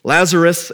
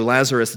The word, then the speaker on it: Lazarus: Lazarus is sick, and they send word to Jesus, and they say, the one that you love is sick.